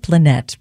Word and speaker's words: Planette: That's spelled P-L-A-N-E-T-T-E. So it looks like Planet, but Planet Automatic from the record Blue Fascination.